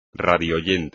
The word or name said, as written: radioyente